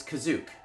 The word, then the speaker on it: Kashyyyk: You're mispronouncing it. It's Kazook. – Huh. – Wait, what? – Okay. – Just wait. They refer to it as Kazook.